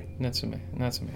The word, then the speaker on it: Natsume: Natsume.